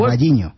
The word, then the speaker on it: Carballiño: Carballiño.